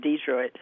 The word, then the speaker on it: Detroit: They came from Arkansas, and the way they pronounce it was Detroit.